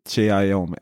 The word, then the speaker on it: Xiaomi: Xiaomi är det uttalas va? Xiaomi, och, ja. – Xiaomi. Ja.